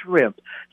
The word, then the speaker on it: shrimp: I want scrimp." The correct way to pronounce that, Kiki, is shrimp.